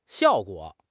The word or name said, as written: xiao4 guo3